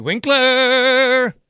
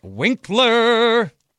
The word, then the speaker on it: Winkler: I'm Bart Winkler. That's actually the phonetically correct way to say my name. Some people say Winkler. Some people say Winkler. It's actually Winkler. You got to like really announce it.